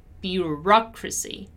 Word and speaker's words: bureaucracy: While in bureaucracy, my stressed syllable is the ra, bureaucracy, bureaucracy, bureaucracy.